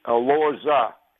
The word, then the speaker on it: Elorza: I mispronounced his name. His name is Alorza. E-L-O-R-Z-A.